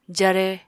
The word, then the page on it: jare: apatani